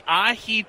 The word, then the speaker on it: ahi: I, am saying it wrong. – No, no, that's right. that's ahi right. tuna. – You're that's right. correct. Ahi is right.